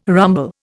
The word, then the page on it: rumble: Indian